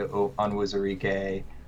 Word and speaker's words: Onwuzurike: You also have some experienced linemen in Jalen Johnson, Levi Onwuzurike.